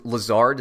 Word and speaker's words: Lazard: Is it Lazard? Is that how you pronounce his name? – Yeah.